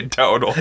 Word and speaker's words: Dowdle: Doodle? Is that how you say it? Doodle? Dowdle? – I would say Dowdle. Dowdle.